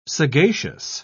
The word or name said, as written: sagacious